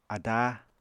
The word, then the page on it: adáa: Apatani